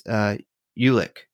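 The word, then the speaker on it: Julek: Let's see, Jolik? – It's not, it doesn't, the J is silent. It's Ulek.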